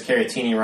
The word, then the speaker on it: Caratini: know they have Darvish, uh, Caratini, which is always fun to cheer for because Caratini rhymes with Martini, and that makes me think back to uh, when I watched One Flew Over the Cuckoo's Nest senior year of high school.